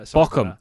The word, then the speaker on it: Bochum: So Bochum, as it's pronounced.